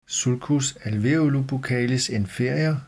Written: sulcus alveolobuccalis inferior

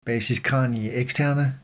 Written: basis cranii externa

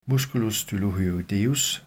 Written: m. stylohyoideus

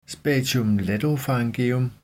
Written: spatium lateropharyngeum

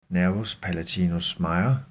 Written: n. palatinus major

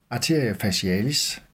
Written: a. facialis

